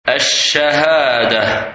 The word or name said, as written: Shahadah